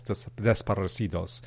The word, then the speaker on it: desaparecidos: desaparecidos.